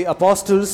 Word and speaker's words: apostles: And the apostles said unto the Lord. – Apostles said unto the Lord, increase our faith. – The T should not be pronounced.